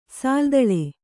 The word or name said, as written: ಸಾಲ್ದಳೆ